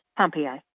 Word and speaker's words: Pompeii: And in Gratiot County, a tiny town that's spelled like the ancient city of Pompeii is pronounced Pompeii.